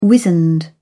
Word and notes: wizened: British English

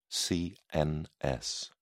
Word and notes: CNS: British English

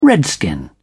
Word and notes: redskin: British English

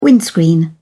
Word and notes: windscreen: British English

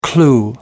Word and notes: clue: American English

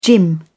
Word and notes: gym: British English

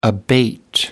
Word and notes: abate: American English

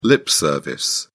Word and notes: lip service: British English

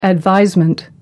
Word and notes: advisement: British English

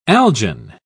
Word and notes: algin: British English

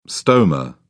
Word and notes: stoma: British English

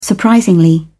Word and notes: surprisingly: British English

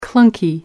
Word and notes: clunky: American English

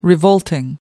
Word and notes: revolting: American English

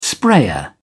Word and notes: sprayer: British English

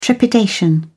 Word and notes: trepidation: British English

